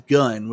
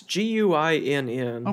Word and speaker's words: Guinn: However, is it Gwynn? I thought it was Gunn was the guy's last name, the author. – It's Guinn.